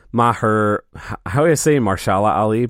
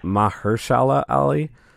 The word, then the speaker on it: Mahershala Ali: Mahershala Ali, but that is actually wrong. It's Mahershala Ali.